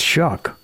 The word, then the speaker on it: Shough: It's spelled S-H-O-U-G-H. That doesn't look like it would be pronounced Shuck.